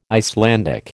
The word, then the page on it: Icelandic: American English